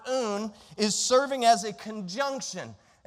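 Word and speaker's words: oon (οὖν): The word therefore comes from the Greek word oon. That's how you say it, oon.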